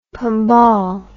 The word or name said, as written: pombal